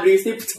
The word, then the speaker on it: receipt: receipt อ้าวไม่ตัว P ไม่ออกเสียง